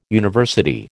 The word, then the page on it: university: American English